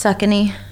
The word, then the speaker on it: Saucony: I'm a big fan of Saucony. I'm sorry. – That's fine. – But Speed Goat. – It's actually pronounced Suckany.